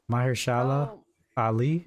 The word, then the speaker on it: Mahershala Ali: Mahershala Ali? – Yes.